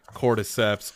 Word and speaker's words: cordyceps: Cordyceps.